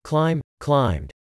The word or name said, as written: Climb / climbed